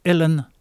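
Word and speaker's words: uilleann: ça se dit Helen Pipes. Helen, c'est du gaélique irlandais, ça s'écrit Willean, mais ça se prononce Helen.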